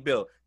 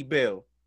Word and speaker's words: Beal: Now let's say you make a deal for Bradley Beal, right? It rhymes.